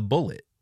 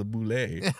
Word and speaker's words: Bulette: Gary Gygax originally nicknamed this monster the Bullet, which Cask mockingly pronounced in a French accent as the Boulet.